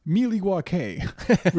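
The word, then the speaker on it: Milwaukee: Actually, Wayne, it's pronounced Miliwake, which means land of the plenty.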